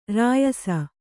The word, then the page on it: ರಾಯಸ: rāyasa; Kannada